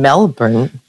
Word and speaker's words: Melbourne: I am proud to be your first virtual guest, but I'm also very sad not to be in Melbourne, Australia.